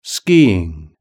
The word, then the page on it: skiing: British English